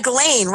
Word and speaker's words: Ghislaine: You know, for the life of me, I do not know how to pronounce her. – I think it's Ghislaine. – Yeah, Ghislaine, right? – Ghislaine.